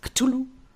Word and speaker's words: Cthulhu: Spricht man das so aus? Cthulhu? – Ja, müsste passen.